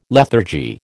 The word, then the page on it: lethargy: American English